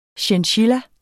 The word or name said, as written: chinchilla